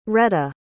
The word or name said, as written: retta